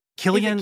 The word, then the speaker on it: Cillian: That, that's soft C. It's just begging you to say Cillian. Guys, you can't put a CI up and expect me to go.